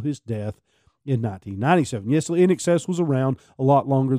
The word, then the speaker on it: INXS: He co-founded the rock band NXS, and I know I'm pronouncing that NXS like excessive.